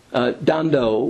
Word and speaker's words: Ndondo: You know, I just got back from Kenya, and many of the names there are spelled with an N that you never pronounce. Dondo, one of Charles's daughters, is spelled N-D-O-N-D-O. Several of the grandchildren, including Anne and Esther, her name is Thenya, is spelled N-T-H-E-N-Y-A.